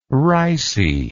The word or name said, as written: ricey